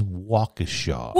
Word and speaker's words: Waukesha: It is Waukesha.